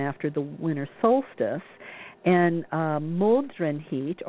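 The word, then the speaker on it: Modraniht: It's actually pronounced something like Moldrenhit, and that's a Germanic holiday, and it's part of Yule festivities.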